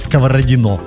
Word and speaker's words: Сковородино: Спасибо, Алексей, за ударение. Теперь будем знать, как правильно говорить Сковородино.